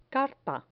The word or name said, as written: carta